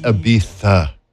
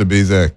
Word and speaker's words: Ibiza: It's Ibiza. – My bad. – Well, that's not what Mike Posner said. – Just kidding. It's Ibiza, because I'm an American. I pronounce a Z like you're supposed to.